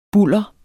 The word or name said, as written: bulder